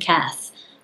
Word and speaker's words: Kath: or just Google Susan Kath. So my last name is spelled K-A-T-H, and you should be able to find me through one of those three ways.